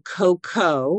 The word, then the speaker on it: cocoa: Let's talk about the difference between cocoa, which is spelled C-O-C-O-A, versus cacao, which is spelled C-A-C-A-O. And I'm going to say them this way slowly so that you'll be able to differentiate the two as I share them with you.